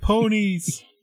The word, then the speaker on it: ponies: And Your Highness, these are the ponies who uh, I spoke with you about and rescued Petey. – There's not emphasis on both syllables of ponies. It's just ponies.